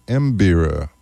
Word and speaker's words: mbira: And Joaquin played that on a mbira, which is a mbira, I believe it's pronounced.